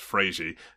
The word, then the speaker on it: Fred: Apparently Fred is pronounced Frazy, but we all just call him Fred.